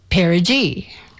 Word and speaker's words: perigee: The pronunciation for perigee is perigee. And I have figured out not only what perigee is and means, but also how to pronounce it and how to remind myself how to pronounce it, which is important. Perigee reminds me of putting on a pair of jeans.